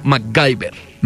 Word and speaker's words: MacGyver: Pero pues ya obviamente lo traducían, ya no era McGiver, sino MacGyver. – ¿MacGyver? – Sí. – Uy, no. – Sí, es que supuestamente se pronunciaba MacGyver.